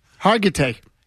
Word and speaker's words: Hargitay: Hargitay.